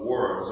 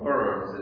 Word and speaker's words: Worms: This is kind of scary in itself, but, So the diet, or this official tribunal, we might say, of Worms, or Worms in German, because of the W is pronounced as a V in German. So the city,